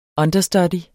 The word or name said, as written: understudy